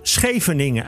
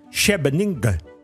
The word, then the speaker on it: Scheveningen: Ze legde uit hoe Japanners Scheveningen uitspreken. Spreken ze uit als Shebeninge.